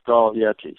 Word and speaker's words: Scaglietti: Yeah, there's no Stag in Scaglietti. So that's how he pronounced it.